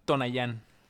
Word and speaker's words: Tonayán: Que te inviten a tomar tonayán. Así, ay, con acento en la ñan.